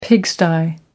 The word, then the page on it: pigsty: American English